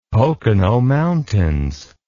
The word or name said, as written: Pocono Mountains